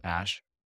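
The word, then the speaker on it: Æ: The A E is pronounced Ash.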